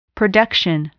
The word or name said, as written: production